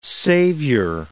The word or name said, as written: savior